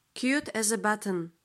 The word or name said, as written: cute as a button